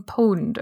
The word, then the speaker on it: Pwned: I think you say the P as an O technically, okay. But everybody says, have you been pwned?